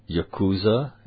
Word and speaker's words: Yakuza: And be careful writing about the Yakuza.